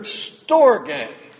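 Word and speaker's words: storge (στοργή): But there's another word they had, the Greeks had, and that was a word, storge. You would spell it in our English language as the word storage. But storge.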